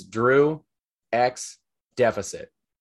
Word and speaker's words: Drew X Deficit: But my name on everything is Drew X Deficit. X is silent. Don't pronounce it, but you respect it.